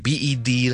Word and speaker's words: bed: 咁呢个系一个好明显嘅效果嚟噶，我俾个例子嚟，譬如 BED 咧同 BET 咧，佢嗰呢两个字嘅分别咧系发音系好大嘅，最大嘅分别咧系在于佢嘅响音嘅长短。 BED 咧我听到系 bed bed bed bed，我哋唔需要急停嘅，但系 BET 咧就系 bed bed bed，听一次咯，BED 系 bed bed bed。